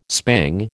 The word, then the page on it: spang: American English